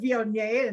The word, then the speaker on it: Viognier: You did well to say mm. Viognier. – I say it Viognier. I don't know. People say Vignier, Viognier. It depends on how many uh, syllables you want to put on the word.